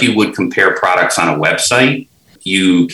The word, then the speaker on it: CompAR: We have another product called, it's pronounced Compare. It's spelled CompAR, so it's pronounced Compare.